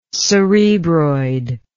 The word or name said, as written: cerebroid